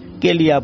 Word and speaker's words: KLIA: KLIA এটার ইলাবোরেশনটা হচ্ছে Kuala Lumpur international airport, মূলত এটাকে অনেকে সংক্ষেপে কেলিয়া বলে, মানে যে যেভাবে উচ্চারণ করতে স্বাচ্ছন্দ্য বোধ করেন সেভাবে উচ্চারণ করেন আর কি।